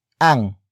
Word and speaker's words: ang: The next word is ang, which is spelled as A-N-G.